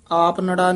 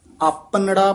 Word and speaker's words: अपनड़ा: इत मार्ग भैने मिली है, अपनड़ा प्रभ नजर कर देखै, आपनड़ा नहीं कहना है, अपनड़ा प्रभ, अपन ते डा दे विच डैश दे के उच्चारण करना है, कस के बोलना है पग।